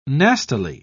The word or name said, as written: nastily